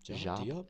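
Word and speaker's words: Diop: Jop? How do you say his name? – Jop? Yeah, it was.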